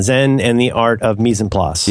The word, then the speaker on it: mise en place: And you've talked so much about like food preparation and mise en place, if I'm saying that right. And yeah, mise en place.